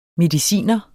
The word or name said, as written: mediciner